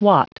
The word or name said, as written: watt